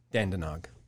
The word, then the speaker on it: Dandenong: That's exactly how they say it, Dandenong.